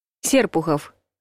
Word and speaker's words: Серпухов: Ударение падает на первый слог – Серпухов.